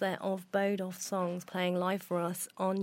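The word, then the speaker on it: Boduf Songs: And we've got Bodoff Songs with us, and I'm actually pronouncing it right, because even though it's spelled B-O-D-U-F, it's pronounced B-O-W-D-U-F, if that makes sense to you.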